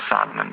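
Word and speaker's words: psalmen: Men till exempel så har vi ord som salm till exempel, eller ljud, där vi då stavar LJ på ljud och salmen med P, men vi uttalar inte P, det är liksom stumt.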